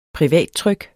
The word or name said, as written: privattryk